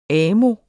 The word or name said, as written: AMO